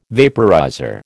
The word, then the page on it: vaporizer: American English